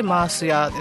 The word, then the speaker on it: 塩屋: 今ちょっとツイキャスの皆さんにはあのこのバッグを見てもらってるんですけど、まあ、塩屋と書いてマース屋ですね。あの沖縄の方言で塩がマースと呼んでおりますのでね、マース屋さんに行ってまいりました、はい、でこれですね、沖縄の会社でして、沖縄県内の塩とか日本や世界各地の塩など豊富に品揃えをされているお店です。